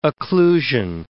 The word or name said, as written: occlusion